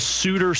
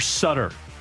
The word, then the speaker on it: Sutter: The Suter Sutter. If you butcher that, because Bruce Sutter with the Cardinals Brian Sutter with the Blues.